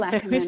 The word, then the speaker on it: Ackerman: is it Ackerman? Did I say that right? – Yes. Yes. – Good. – Yes. Will Ackerman.